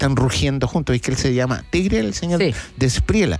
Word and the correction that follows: de la Espriela: ¿Así se pronuncia? ¿De Espriela? – De la Espriela.